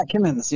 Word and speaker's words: Kimmons: That's how you pronounce it, right, Kimmons? – Yeah, Kimmons, yep.